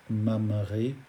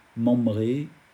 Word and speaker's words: Mamré: Alors néanmoins, pour l'indulgence, quand on prononce très vite « mamré » et qu'on ne sait pas que ça se dit « mam » mais on le dit « man »,« membré », on a l'impression d'entendre un « b ».